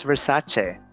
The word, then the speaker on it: Versace: Versace. It's Versace.